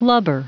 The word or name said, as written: lubber